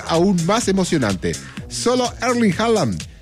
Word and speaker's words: Haaland: Erling Haaland, o Haaland, así se dice, Haaland, así se pronuncia, dice que, de Noruega, ya con 7 goles, ya no tiene ninguna posibilidad.